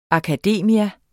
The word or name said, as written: akademia